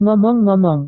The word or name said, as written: ngomong-ngomong